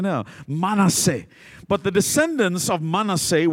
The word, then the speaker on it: Manasseh: It's not pronounced Manasseh, it's Manasseh.